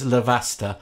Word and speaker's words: Lavazza: Apparently, it's Lavazza. Lavazza. – That's the one. – Yeah. Sorry. Yeah. I've always been saying Lavazza. I've literally been saying it wrong my entire life.